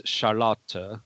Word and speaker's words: Charlotte: That is pronounced here as Charlotte, because that would be the French pronunciation. I'm not sure what the German pronunciation would be.